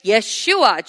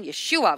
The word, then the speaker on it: Yeshua: So when the man claiming to be the Son of God coming in the name Yeshua, Jesus in English, but Yeshua in Aramaic pronunciation, Yeshua, which means the Savior, he said what?